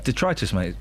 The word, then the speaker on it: detritus: Detritus? How do you say it? – Detritus, mate. That's how you say it.